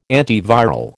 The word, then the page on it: antiviral: American English